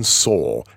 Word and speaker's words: Sohl: Anyway, having said that, Astro Smash was designed by John Soll. I think that's how it's pronounced, S-O-H-L.